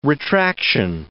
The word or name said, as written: retraction